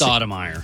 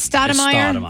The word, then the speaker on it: Stoudemire: The Stoudemire!